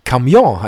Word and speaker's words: camion: Und bei Camion ist es eben auch letztendlich ein französisches Wort, das nämlich Camion heißt, wo man die Betonung auf der zweiten Silbe hat und das auch im Französischen wesentlich schöner klingt.